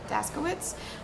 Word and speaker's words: Daskowics: His first name is Grayson, which is just G-R-A-Y-S-O-N. His last name is D-A-S-K-O-W-I-C-S, Daskowitz, hyphen Davis.